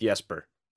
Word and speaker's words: Jesper: I want to say Sweden, so it's pronounced Jesper, but yeah.